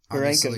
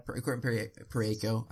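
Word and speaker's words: Parayko: I mean, the Colton Perriaco – Perenco, yeah. – Perenco, I always say that wrong.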